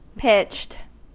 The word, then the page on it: pitched: American English